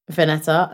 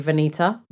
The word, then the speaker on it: Veneta: I think the other thing I really wanted to talk about is the resurgence of Bottega Veneta. Veneta, allegedly, that's how it's pronounced. Don't come for my neck, but I can give you Veneta.